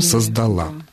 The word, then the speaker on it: создала: Ну, а в женском по-прежнему ударение на последнее. А в женском «создала». Да.